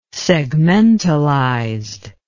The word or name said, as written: segmentalized